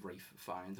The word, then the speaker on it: Ralph Fiennes: Like one of the hosts is called Ralph, and he's annoyed that like Rafe, you know, Ralph Fiennes, is Rafe Fiennes, and it's spelled exactly the same.